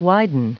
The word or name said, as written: widen